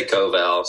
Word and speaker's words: Koval: Is your last name, you pronounce it Koval, correct? Koval? – I say Koval, so.